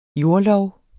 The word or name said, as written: jordlov